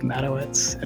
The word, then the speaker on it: Madowitz: That's a shorter string of words, but I have a Polish last name, so it's Mike Matowitz at Twitter.